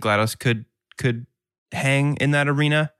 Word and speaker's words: GLaDOS: GLaDOS.